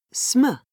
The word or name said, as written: sm